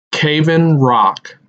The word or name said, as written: Cave In Rock